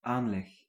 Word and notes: disposition: [ˌdɪs.pə.ˈzɪ.ʃən]

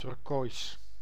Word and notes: turquoise: British English; /ˈtɜː(ɹ).k(w)ɔɪz/

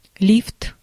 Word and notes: лифт: /ˈlʲift/

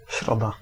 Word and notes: mercredi: France; [mɛʁ.kʁə.d͡zi]

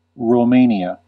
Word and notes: Romania: American English; /ɹoʊˈmeɪ.ni.ə/

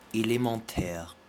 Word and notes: élémentaire: [e.le.mɑ̃.tʰɛʁ]; [e.le.mɑ̃.tɛʁ]

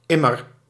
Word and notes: toujours: France; [tu.ʒuʁ]

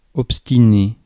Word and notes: sullen: American English; [ˈsʌ.lən]

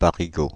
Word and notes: Parigot: /pa.ʁi.ɡo/

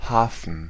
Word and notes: port: [pɔɹt]

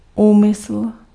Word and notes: intention: /ɪnˈtɛnʃən/

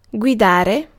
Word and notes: guide: /ɡaɪd/

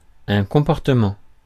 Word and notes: comportement: France; /kɔ̃.pɔʁ.tə.mɑ̃/

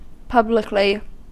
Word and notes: publicly: [ˈpʌb.lɪk.li]; American English

